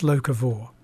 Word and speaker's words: locavore: And is it pronounced locavore? – It is pronounced locavore.